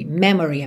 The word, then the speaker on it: memory: Atenção que esse O é praticamente mudo, tá? Você não fala memory. Memory. Memory. Memory. Memory. Câmera lenta. Memory. Tá? Então, memory. E a sílaba tônica é a primeira. Me.